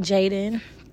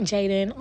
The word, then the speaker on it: Jadan: It is Jubilant Jaden. J-U-B-I-L-A-N-T. I know y'all know how to spell Jubilant, but just in case you didn't. Jubilant. And then my name is spelled like J-A-D-A-N. Jubilant Jaden on Instagram.